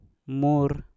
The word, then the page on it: mur: Mpur